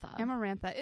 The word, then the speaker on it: Amarantha: Amarantha. – Yes. Amarantha.